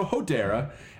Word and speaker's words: Hodera: Hodera. It's like Hodor from Game of Thrones, just with an A at the end. Hodera. – Well, no, but with an E before the R.